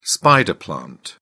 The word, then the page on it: spider plant: British English